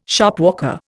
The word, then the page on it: Shopwalker: Indian